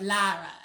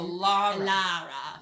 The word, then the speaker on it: Lara: Like Lara. L-A-R-A. – Lara. Now I know her. – You have to say it like that too. You can't be like Laura. It's going to be Lara.